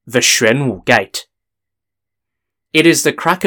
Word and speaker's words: Xuanwu: Xuanwu is spelt X-U-A-N-W-U, and in Chinese, this event is known as Xuanwu Men Zhi Bian.